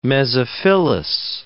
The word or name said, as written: mesophyllous